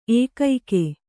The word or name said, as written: ಏಕೈಕ